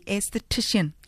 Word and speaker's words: aesthetician: First things first, did I pronounce it okay? Aesthetician. – Yes. – Okay. – Yes, perfect.